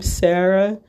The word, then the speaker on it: Sarah: So again, that's my name, Sarah, spelt with an H at the end of it, underscore, and the word journalist.